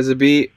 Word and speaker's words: Zabit: Did I say his name, Zabit? – Yeah, Zabit Magomed Sharipov.